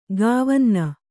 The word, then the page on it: ಕ್ವಿನೀನು: kvinīnu